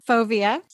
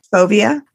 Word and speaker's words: Fovea: Fovea. – Fovea.